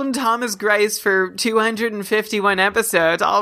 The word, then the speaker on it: Thomas: Patty's telling us that it's just Thomas. I've been saying Tomas. You said Tomas at one point.